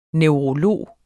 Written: neurolog

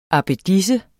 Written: abbedisse

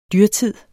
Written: dyrtid

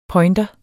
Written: pointer

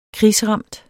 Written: krigsramt

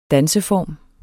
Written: danseform

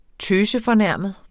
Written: tøsefornærmet

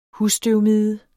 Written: husstøvmide

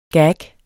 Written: gag

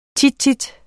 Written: tit

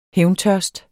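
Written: HÆVNTØRST

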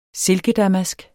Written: silkedamask

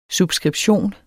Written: subskription

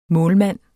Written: målmand

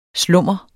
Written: slummer

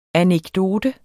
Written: anekdote

